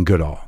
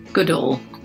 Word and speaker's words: Goodall: This is me talking to Jane Goodall. I was looking at my paper thinking, how do I say this? – Goodall. – Goodall. – Just like all good, you know? – Yes, of course.